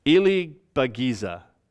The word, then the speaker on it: Ilibagiza: A couple weeks ago shared about, I mess up her name, Immaculée, do you know how to say her last name? Ilibagiza. I think that's it.